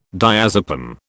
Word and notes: diazepam: British English

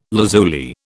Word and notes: lazuli: British English

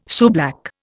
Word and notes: shoeblack: Indian

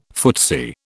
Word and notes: footsie: British English

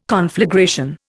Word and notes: conflagration: Indian

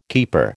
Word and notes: keeper: American English

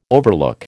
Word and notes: overlook: American English

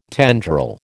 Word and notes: tendril: American English

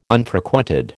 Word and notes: Unfrequented: American English

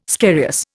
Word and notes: scarious: Indian

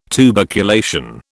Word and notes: tuberculation: British English

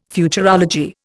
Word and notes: Futurology: Indian

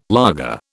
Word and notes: laager: British English

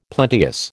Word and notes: plenteous: American English

